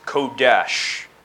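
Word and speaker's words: Kodesh (קודש): The Hebrew word for holy is pronounced Kodesh, but that is spelled Q-O-D-E-S-H. And that is the Hebrew word. And then in Greek, it's hagios, H-A-G-I-O-S.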